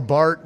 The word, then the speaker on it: Barth: Karl Barth was no fan of this kind of distinction or other important distinctions. And you may or may not know who Karl Barth is, written Barth, but pronounced Bart.